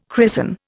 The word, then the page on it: christen: Indian